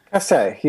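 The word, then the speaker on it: Casé: Were you familiar with Regina Casse? I think that's how you pronounce it, Casse. – Casse, yeah. I think so.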